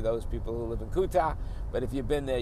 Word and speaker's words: Kuta: We stayed in Kuta, which is spelled K-U-T-U, I believe, but it's pronounced Kuta.